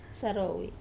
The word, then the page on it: sarowi: Wamesa